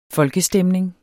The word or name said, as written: folkestemning